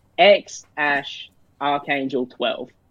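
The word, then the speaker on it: X Æ A-12: So the way that Elon pronounces it is X Ash Archangel 12.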